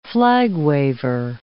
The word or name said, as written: flag-waver